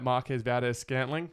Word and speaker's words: Marquez Valdes-Scantling: Your mate Marquez Valdes-Scantling.